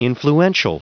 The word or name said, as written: influential